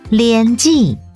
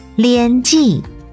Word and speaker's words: 莲子: 秋天是采收莲子的季节，莲子台语念作莲记，莲记客语念作莲子，莲子。